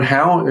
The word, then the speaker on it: Hau: My name is Irwin Howe. Irwin is in Steve Irwin, so IRWIN. Howe is in how do you do, but pronounced that way, but spelled HAU.